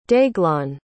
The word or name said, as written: deaglan